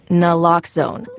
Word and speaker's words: Naloxone: For the record, it's Naloxone. Nuh, like, is Naloxone addictive? Nuh-uh. Lox, like Naloxone keeps humans safe, like Lox keep bikes safe. And own, like Naloxone can save lives in our very own communities.